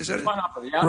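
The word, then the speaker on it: Schweighöfer: It's Matthias Schweighofer. Is that it? – Schweighofer, right.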